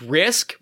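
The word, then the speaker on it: Risqué: So the falcon's name is Risk, but it's spelled risque, like R-I-S-Q-U-E, because it's a little cooler.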